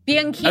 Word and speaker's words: Bianchini: Bianchini.